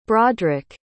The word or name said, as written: brodric